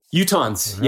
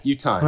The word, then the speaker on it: Utahns: Because I don't know, is it Utahns? I don't know how to say it correctly. – Utahns. Right. Yeah. Utahns. Wow. That's it.